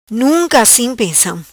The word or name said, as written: Pensam Nunca Assim